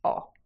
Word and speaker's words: ho: That's I have, spelt H-O, but the H is silent. So I have is O.